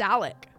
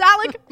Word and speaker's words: Alex: And they all say Alec. – But it is Alex. – It's Alec Murdaugh. Murdoch. It's Alec Murdoch. – Well, it's A-L-E-X.